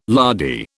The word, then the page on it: lardy: British English